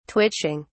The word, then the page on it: twitching: British English